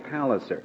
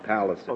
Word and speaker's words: Palliser: Yeah, I think it's Palliser. – Oh, Palliser. – Have you ever read the Trollope novels, the Palliser novels? I think those are always pronounced Palliser.